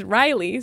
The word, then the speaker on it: Reilly: My name is spelled R-E-I-L-L-Y. – Are you okay? – That's it. My Reddit handle is Riley, spelled my way, not R-A-L-E-Y.